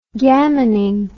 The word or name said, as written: gammoning